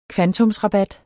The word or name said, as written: kvantumsrabat